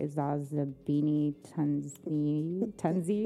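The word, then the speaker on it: Zozibini Tunzi: Zazabini Tunzi.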